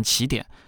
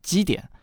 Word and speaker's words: 奇点: 如果取前者的意思，那就可以念成起点；但如果取后者的意思的话，就可以念基点。